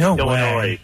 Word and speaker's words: Illinois: It's Illinois, people. People actually say Illinois? – Illinois. It's a silent S.